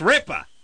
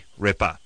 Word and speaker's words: Ripa: You're mispronouncing the person's last name. It's not Ripper.